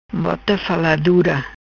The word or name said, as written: Bota Faladura